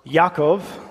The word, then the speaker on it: Yaakov: My great-grandfather was named Jacob, or Yaakov, as it would have been pronounced in his native Croatian.